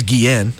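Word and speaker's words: Guillén: Last name is Guillen. I know that because it's like Ozzy.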